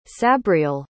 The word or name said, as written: sabriel